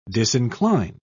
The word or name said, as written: disincline